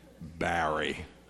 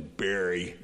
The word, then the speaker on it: Barry: I did not ask you where you are from, but you pronounce my name like it's pronounced in West Tennessee, Barry. Anyway, I'm not sure where you're from, but anyway, you speak like my people speak, so I was glad to hear that rather than Barry, B-E-R-Y. You said it correctly.